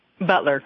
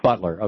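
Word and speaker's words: Beutler: Your congresswoman is, tell me if I'm pronouncing this right, Jamie Herrera, is it Butler or Butler? – Butler. – Butler. Okay. Butler.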